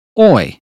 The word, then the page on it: boy: British English